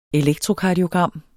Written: ELEKTROKARDIOGRAM

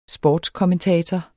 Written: sportskommentator

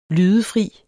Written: lydefri

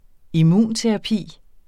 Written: immunterapi

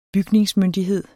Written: Bygningsmyndighed

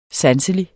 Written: sanselig

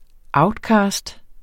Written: outcast

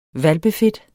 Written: hvalpefedt

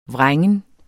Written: vrængen

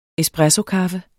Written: espressokaffe

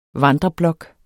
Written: vandreblok

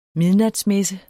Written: midnatsmesse

